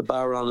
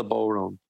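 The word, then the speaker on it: bodhrán: The Baron is the drum you play, not the Boron.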